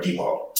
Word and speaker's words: DePaul: I did DuPaul, their theater conservatory, which was also out here. – DuPaul or DePaul? DePaul. – Yeah, DePaul.